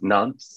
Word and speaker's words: Nantes: Nantes? – Nantes.